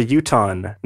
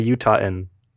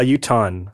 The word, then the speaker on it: Utahn: The word for a person from Utah is a Utahn, not a Utahan. – Oh, I didn't know either. – People get upset they both sound strange. A Utahn? – A Utahn, so two syllables.